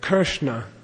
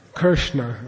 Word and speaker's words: Krishna: Krishna, okay. – Krishna. – If you want to be really cool, you turn your tongue back.